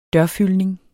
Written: dørfyldning

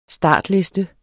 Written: startliste

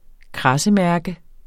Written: kradsemærke

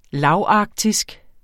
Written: lavarktisk

